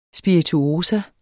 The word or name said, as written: spirituosa